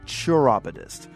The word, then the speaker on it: chiropodist: You see, Zachary was Lincoln's chiropodist, or as the Brits say, churopodist.